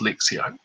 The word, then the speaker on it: Lectio: Lectio Divina, but it's called pronounced Lectio.